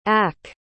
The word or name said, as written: ack